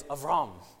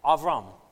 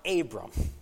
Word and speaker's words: Abram: Additionally, because my Hebrew professor is in the room today, the correct pronunciation of Abram is Avram, okay? Avram. It's not Abram.